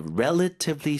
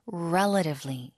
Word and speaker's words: relatively: ETF가 있으면 with ETFs you can invest in many companies with a relatively small amount. 발음 좀 도와주세요. – Relatively 하셔도 되고요. T를 부드럽게 해서 relatively, relatively. – relatively. – 앞에 힘 주고 relatively.